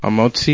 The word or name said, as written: hamotzi